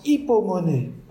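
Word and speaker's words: hypomonée (ὑπομονή): » Et on va revenir à l'écran avec le mot qui se prononce « hypomonée ».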